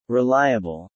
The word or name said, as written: reliable